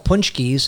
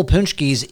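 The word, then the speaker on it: pączki: You're literally putting broken up punschkes and whole punschkes into the distiller, into the still? – Yes. Yes. – Am I saying that right? – You're saying that exactly right.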